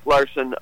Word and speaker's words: Larsen: And by the way, Larson is spelled LARSEN, not O-N. – I had to learn it. – Oh, yeah, everyone misspells it the first time. O-N is the more common spelling of the name.